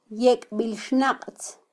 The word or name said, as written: yekbilxnaqtz